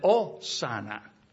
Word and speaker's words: Hosanna: And Spanish is mute, so we pronounce it Osana rather than the Hosanna.